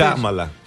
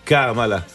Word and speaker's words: Κάμαλα: Κάμαλα. – Ναι. – Κάμαλα. Το είπα σωστά, εντάξει.